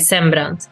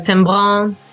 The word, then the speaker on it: Sembrant: So you should pronounce it like Sembrant. But people in France, they're like Sembrant. Or like in Italy, they go like Sembrant with the R.